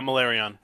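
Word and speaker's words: Malerion: But her and Slaanesh both decided for Marathi to put her son, and I'm going to butcher this name, Malrion? Malrion? Correct me? – What now? – Malarion? – Yeah, Malarion.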